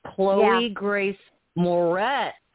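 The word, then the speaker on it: Chloë Grace Moretz: No, that's one we have to start with the actress, Chloe Grace Moretz. I said it right this time, Carl. – Yes, you did.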